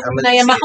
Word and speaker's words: Niamh: So this one that is N-I-A-M-H, the correct way to pronounce it according to this is actually Niamh. Or Niamh. – There's a fucking V in there?